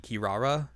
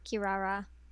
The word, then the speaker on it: Kirara: I don't know which one you want to go with, because I mean the Japanese pronunciation would be Kirara. – Yes. – Presumably. – Yes, Kirara.